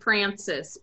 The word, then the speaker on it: Francois: Can I tell you it's called St. Francis, but Francois sounds fancier. – Well, it's spelled F-R-A-N-C-O-I-S.